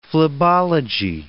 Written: phlebology

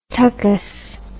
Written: tuchus

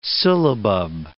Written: syllabub

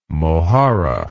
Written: mojarra

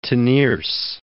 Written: Teniers

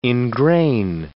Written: ingrain

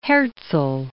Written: Herzl

